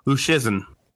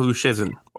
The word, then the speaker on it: Luszczyszyn: I'm pretty confident saying that. Uh, I know there's a lot of extra letters in there, but I'm pretty sure it's Luszczyszyn.